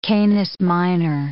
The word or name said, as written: Canis Minor